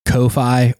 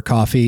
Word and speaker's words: Ko-fi: And you can go and find Scout's other artwork or commission her for a piece at her coffee page. That's what it's pronounced, right? – I think so. I've heard it pronounced Ko-fi or coffee.